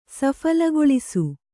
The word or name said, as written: ಸಫಲಗೊಳಿಸು